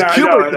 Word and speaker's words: Kubert: This isn't Stephen Colbert, man. This is Kubert, the Kubert School, man.